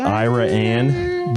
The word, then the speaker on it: Iraan: They call it Iran, but it's spelled I-R-A-A-N, which is not the same as Iran, I realize.